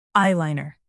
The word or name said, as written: eyeliner